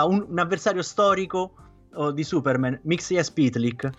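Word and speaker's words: Mxyzptlk: Sì, ma fondamentalmente si pronuncia, almeno dopo hanno fornito l'esempio, è molto simile alla pronuncia straniera fatta da un giapponese. Cioè ci metti ogni tanto qualche vocale in mezzo per riuscire a pronunciare. Infatti diventa Mixias Pytlik.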